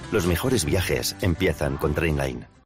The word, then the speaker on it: Trainline: Se pronuncia Trainline.